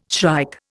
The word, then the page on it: shrike: Indian